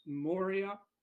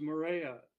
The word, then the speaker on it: Moria: It's technically pronounced Moria, but the name of the song is Morea.